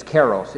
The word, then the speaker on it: kairos: But there's another concept, I don't know how you pronounce it, I just read it, but it's kairos. Is it kairos? – Kairos, yeah.